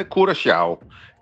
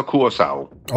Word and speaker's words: Curaçao: Ja, hvis du må tage det helt korrekt, så siger det, hedder Curaçao. Det der C med den lille krølle under, det får sådan en sjav-lyd, men langt de fleste, de udtaler det blot som et S, C, så Curaçao.